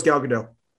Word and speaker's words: Gal Gadot: Is it Gal Gadot or Gal Gadot? She goes Gal Gadot. Gal Gadot.